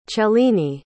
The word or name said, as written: cellini